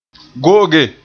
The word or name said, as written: go'oge